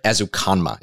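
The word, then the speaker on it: Ezukanma: In the fifth round, I was able to grab Texas Tech wide receiver Eric Azukanma is the way to pronounce his name.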